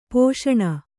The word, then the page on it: ಪೋಷಣ: kannada